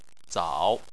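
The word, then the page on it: zao: tone 3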